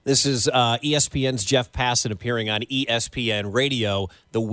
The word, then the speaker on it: Passan: Passan.